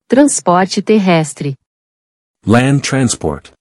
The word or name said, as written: Transporte terrestre